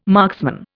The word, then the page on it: marksman: Indian